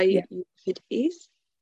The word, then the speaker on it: Euripides: the ancient Greek tragedy or revenge play by Euripides. Is that how you say his name? – Yeah, that sounds right.